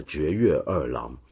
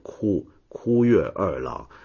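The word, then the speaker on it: 堀越二郎: 然后我就介绍到宫崎骏上一部电影《风起了》里面的男主角就是真人啊，就历史上日本零式战机的设计师，他的名字呢我念成了绝月二郎，结果被很多朋友指正了，不是念绝，是念枯月二郎。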